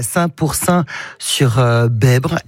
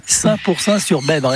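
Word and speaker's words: Saint-Pourçain-sur-Besbre: Et oui, notre baladeur qui s'installe au Pal à Saint-Pourçain sur Bèbre. L'ai-je bien dit? – Parfaite diction, même prononciation. Oui, Saint-Pourçain sur Bèbre, exactement.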